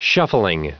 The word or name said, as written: shuffling